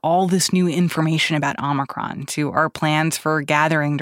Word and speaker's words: Omicron: I've been saying Omicron because that's what the dictionary says is the American pronunciation, but I recognize that a lot of other people are saying Omicron, so I think that this is a safe space for various pronunciations of this variant.